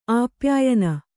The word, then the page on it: ಆಪ್ಯಾಯಿನಿ: āpyāyini